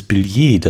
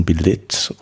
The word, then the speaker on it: Billet: Ist das denn überhaupt so richtig ausgesprochen oder ist das ein Billett oder na Billett bestimmt nicht, aber ich würde ich, hab, äh, ich hätte Billet. Billet, ja. Billet.